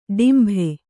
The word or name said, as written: ಡೊಂಬೆ